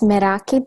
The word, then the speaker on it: Meraki: Is it Meraki? Is that how you say it? – It's Meraki.